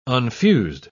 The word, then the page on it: unfused: British English